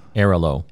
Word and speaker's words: Airalo: Air-a-lo.